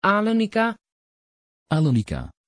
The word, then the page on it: Alenika: Dutch